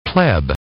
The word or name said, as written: pleb